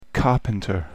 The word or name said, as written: carpenter